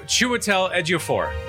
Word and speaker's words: Chiwetel Ejiofor: We have Chiwetel Ejiofor.